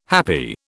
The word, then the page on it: happi: British English